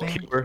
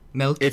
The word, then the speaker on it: Melchior: I don't know how to pronounce the last Melchior. – Name. Melchior.